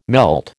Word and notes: melt: American English

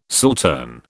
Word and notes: saltern: British English

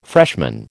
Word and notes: freshman: American English

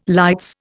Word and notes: lights: Indian

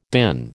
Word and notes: DIN: American English

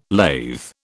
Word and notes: lathe: British English

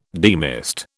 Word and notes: demist: British English

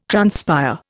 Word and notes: transpire: Indian

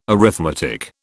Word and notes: arithmetic: British English

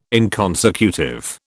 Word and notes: inconsecutive: British English

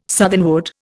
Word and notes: southernwood: Indian

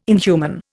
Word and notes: inhuman: Indian

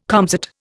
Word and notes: comsat: Indian

